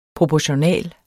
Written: proportional

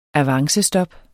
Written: avancestop

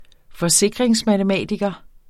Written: forsikringsmatematiker

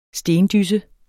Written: stendysse

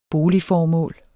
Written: boligformål

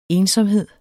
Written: ensomhed

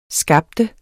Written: skabe

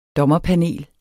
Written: dommerpanel

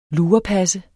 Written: LUREPAS

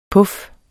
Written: puffen

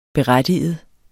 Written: berettiget

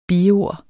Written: biord

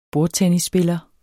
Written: bordtennisspiller

